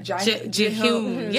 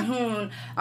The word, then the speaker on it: Ji-hoon: Ji-hoon. Yeah. – Ji-hoon.